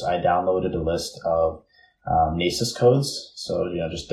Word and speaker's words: NAICS: For people who don't know, you can easily find that it's spelled NAICS.